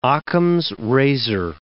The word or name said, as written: Occam's razor